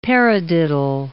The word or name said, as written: paradiddle